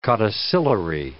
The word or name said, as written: codicillary